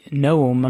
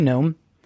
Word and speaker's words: Noem: Unfortunately, their governor, Kristi Noem, I'm not sure I'm saying that right. It's N-O-E-M,